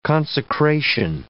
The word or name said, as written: consecration